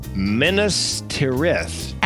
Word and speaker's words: Minas Tirith: Look no further than, and I had to look up the pronunciation, Minas Tirith.